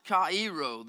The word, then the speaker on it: chairo: This word is the Greek word is spelled C-H-A-I-R-O, but it is pronounced kairo. The C-H-A, ka, I is pronounced as a long double E.